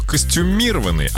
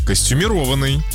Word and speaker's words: костюмированный: Лариса нам написала, она говорит, что до сих пор многие неправильно произносят слово костюмированный, а правильно костюмированный.